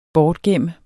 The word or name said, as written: bortgemt